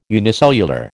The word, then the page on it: unicellular: American English